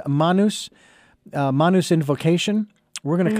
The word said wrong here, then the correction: Namu: Namu. – With Namu an N, not an M. Namu. I reversed the letters there.